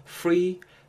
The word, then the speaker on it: free: free 발음하실 때의 F 사운드입니다. P 사운드가 아니고 free, free 윈니 아랫입술에 살짝 걸치고 이렇게 해서 Are you free this Thursday?